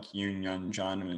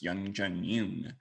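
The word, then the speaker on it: Youn Yuh-jung: I mean, I especially like how she pointed out how Brad Pitt actually pronounced her name right, because I think a lot of people pronounced it like Yoon Young John, except it's Young Jun Yoon.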